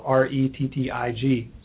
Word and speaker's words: Rettig: My last name is spelled Rettig, so you can find me there.